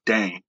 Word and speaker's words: Dane: So I always have to be, you know, polite, but stern, and be like, hey, it's Dane. It's not Dana. It's not Dante.